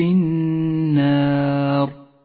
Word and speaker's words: النار: النار اللام الشمسية تكتب ولا تلفظ ولذلك